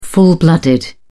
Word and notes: full-blooded: British English

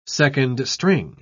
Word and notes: second-string: British English

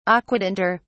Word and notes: aquatinter: British English

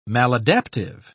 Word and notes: maladaptive: British English

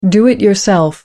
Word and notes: do-it-yourself: American English

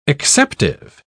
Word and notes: acceptive: British English

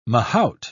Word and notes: mahout: British English

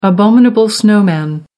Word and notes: abominable snowman: American English

